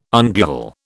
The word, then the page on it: ungual: British English